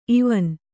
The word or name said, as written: ಇವನ್